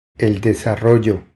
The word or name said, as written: El desarrollo